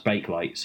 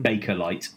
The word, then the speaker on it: Bakelite: I believe it's pronounced bake light, so that's another one of those Nesquilk, Dracula, Baker-like type pronunciations that we've had. – Oh, I actually don't know. Because how's that? Is that written with an E? – Yeah, it's spelled B-A-K-E-L-I-T-E. But he pronounced it Baker-like.